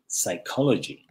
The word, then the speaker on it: psychology: Hemos visto también la de psychology, ¿no? No psychology, no con P.